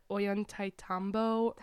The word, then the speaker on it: Ollantaytambo: I think it was called I'm gonna butcher the name, but Ollantaytambo. – That was pretty good. I like the you did the double L thing.